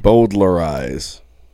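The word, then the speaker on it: bowdlerize: B-O-W. – Oh, Jesus. – D-L-E-R. I-Z-E. – Boulderize. – Yeah, but the L is silent.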